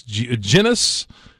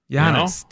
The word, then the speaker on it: Giannis: And they're making Giannis, Genis. No. He's going to be the MVP, hopefully. But with people talking like that, get the name right. – Right. Giannis.